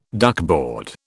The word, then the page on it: duckboard: British English